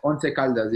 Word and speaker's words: Once Caldas: I mean, you were like Carlos said, you're at, how do you pronounce it? Once Caldas. – Oh, Once Caldas. Yeah.